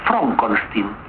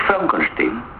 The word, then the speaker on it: Frankenstein: ¿Y por qué no es Frederick Frankenstein? – ¿Por qué no? Es Frederick Frankenstein.